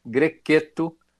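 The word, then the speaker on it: Grechetto: Grequeto, que se escreve com CH e dois T's, G-R-E-C-H-E-T-T-O. Carricante com dois R's, eu estou pronunciando como italiano, mas seria Carricante. Carricante, C-A-R-R-I-C-A-N-T-E.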